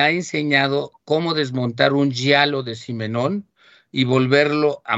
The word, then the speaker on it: giallo: Hay una cosa importante, es un género que en italiano se llama, a ver si lo pronuncio bien, hasta estuve buscando la pronunciación exacta, giallo. Se escribe Giallo y quiere decir amarillo.